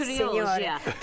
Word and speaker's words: Trilogia: I had Trilogia, which is, is that the right pronunciation, Trilogia. – Signora? – Perfect. Mm-hmm. Trilogia.